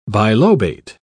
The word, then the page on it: bilobate: British English